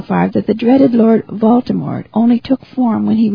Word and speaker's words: Voldemort: Now, this name, Voldemort, I may not be pronouncing it as Harry Potter's author wants us to, and I always want to put an R in the middle of the name, and I stopped right here and looked in the book again to be sure I was spelling it correctly. It's V-O-L-D-E-M-O-R-T, and I guess it's pronounced Voldemort.